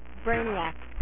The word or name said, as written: brainiac